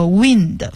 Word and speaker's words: wind: 好，最后一个是 winding，这个 wind W-I-N-D 大家比较熟悉的是那个 wind 风这个词，但是呢它发音发成 wind，它是一个动词，是上弦的意思，就是比如说原来老式的钟或者手表你要上弦它才能够走，那就是上发条，那么这个是 wind。